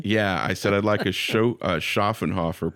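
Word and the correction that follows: Schöfferhofer: please. And she said, Schaffenhofer? I'm like, yeah, that. She says Schaffenhofer.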